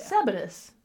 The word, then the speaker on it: Sabattus: S-A-B-A-T-T-U-S. – But spell it slower. But how am I supposed to get that? – S-A-B. – Sab. – A. – T-T. – U-S. – Us. Yeah. Sabatus.